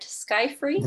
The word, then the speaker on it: Skyfri: Am I pronouncing it right? Skyfree? – That's right.